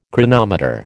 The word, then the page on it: chronometer: American English